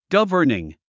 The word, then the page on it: governing: ɡʌ́və r niŋ; ガバニン